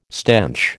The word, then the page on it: stench: American English